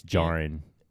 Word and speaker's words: Djarin: It reminds me of Jango, like how it's DJARIN. – It's the Jaren, though, right? – It's Jaren. Yeah. – It's a silent D? – Yeah.